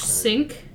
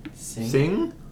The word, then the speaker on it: Singh: Ash Sink is... – why do you say it like that? – Ash Sink. – Sink. S-I-N-G-H. Sink. – Sing? – S-I-N-G-H. That's, how's that sink? – I don't know. Sing.